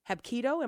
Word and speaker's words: Hapkido: A student of the martial arts discipline, Hapkido.